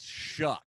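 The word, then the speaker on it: Shough: Rhymes with duck. It's shuck. And shuck.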